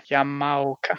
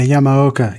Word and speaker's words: Yamaoka: Y-A-M-A-O-K-A. Yamaoka. – Yeah, Yamaoka. Yeah. It's a flat sound. Yamaoka.